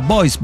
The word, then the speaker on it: Boise: Nunca entendí cómo se pronuncia eso. Si alguien de Idaho me puede explicar cómo se pronuncia. Porque es francés, Boise. – Sí. – Se pronuncia Boise. Sí, se pronuncia así.